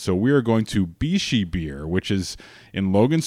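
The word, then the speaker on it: Bixi: And a matter of fact, if you get the jump on this and you go to Bishi Beer, it's B-I-X-I. It's how you spell it if you're looking it up.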